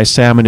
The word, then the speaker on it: salmon: Thank you to Michael Sheehan of Cedar, Michigan, for why at least 75% of why salmon is pronounced with a silent L.